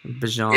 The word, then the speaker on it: Bijan: So I want to say I pronounced Robinson's first name wrong. – Yeah, I thought you did. I couldn't tell if you said Brian or not, but it's Bijan. Bijan. – Yeah, Bijan, Brian, I apologize.